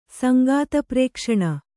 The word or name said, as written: ಸಂಗಾತಪ್ರೇಕ್ಷಣ